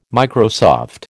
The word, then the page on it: Microsoft: American English